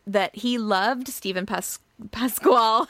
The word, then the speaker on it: Pasquale: I never know how to say his last name. – Pasquale. – Yeah. Okay, I'm going to hope that's right.